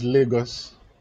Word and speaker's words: Lagos: Is it Lagos, Lagos? – Yeah, it's Lagos.